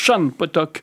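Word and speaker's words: Sian Potok: Ça s'écrit Sian, mais ça se prononce, oui, Shan Potok.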